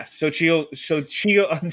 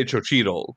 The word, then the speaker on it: Xochitl: Because I want to say Xochitl, because I know in Portuguese, the X is a ch sound, but I know that it's not.